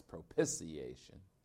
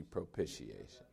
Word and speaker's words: propitiation: No matter how many times I tell him, he always says propitiation, which drives me absolutely crazy. No matter how many times I tell him. But it's actually propitiation.